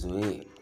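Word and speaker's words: zoe (ζωή): It is pronounced Z-O-A, that is zoe.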